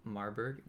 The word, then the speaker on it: Marburg: Greetings from Marburg, Germany.